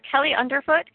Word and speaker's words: Kelly Underfoot: Kelly Underfoot, K-E-L-L-Y-U-N-D-E-R-F-O-O-T.